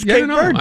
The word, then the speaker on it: Cape Verde: How do you pronounce Cape Verde? – It's Cape Verde, isn't it? – I heard something different, like Cabo or Cabe Verde. – No, it's Cape Verde.